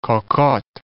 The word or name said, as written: cocottes